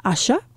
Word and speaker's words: Asa: Asha is her name, A-S-A, but it's pronounced Asha.